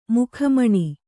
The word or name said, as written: ಮುಖಮಣಿ